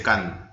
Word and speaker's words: Cannes: Ustedes saben que no se dice Cannes, ¿no? Se dice Cannes.